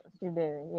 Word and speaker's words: Osiberu: I hope I pronounced that name well, yeah. – Shola. – Osiberu, yeah.